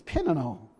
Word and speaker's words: Peninnah: And I looked up how you pronounce her name, and it's Peninnah.